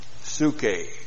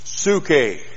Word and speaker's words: Psuche: Psuche. P-S-U-C-H, long E. Psuche.